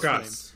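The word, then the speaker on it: Csokas: And he was played by Martin Sokas. Sokas? I'm not sure how to pronounce his last Chukas.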